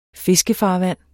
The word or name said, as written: fiskefarvand